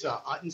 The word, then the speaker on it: Utton: This is Jason Utton from Atwell. Thanks a lot for the time today, Commissioners. – And Jason, what's your last name? – It's Utton, sir. It's spelled U as in uniform, T-T-O-N.